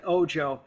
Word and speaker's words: Ojo: He pronounced it Ojo. – Okay. – In Spanish, Ojo is ojo, which means eye, mm-hmm. and the monster has a giant eye, but Sam pronounced it Ojo. So as far as I'm concerned, all right, I guess Ojo it is.